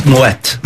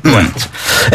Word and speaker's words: Moët: And yeah, if anyone's wondering about the pronunciation, it's because there's a two little dots over the E, which means it's you pronounce both syllables of the word, so it's Moët. Moët.